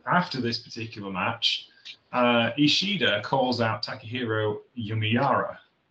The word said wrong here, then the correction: Yamamura: Yamamura, I should pronounce his name correctly.